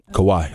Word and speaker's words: Kawhi: How do you say his name? Kawhi? – Kawhi. – Kawhi. – Yeah, yeah.